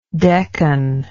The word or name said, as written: decan